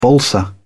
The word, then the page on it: balsa wood: British English